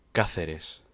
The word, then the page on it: Cáceres: Spanish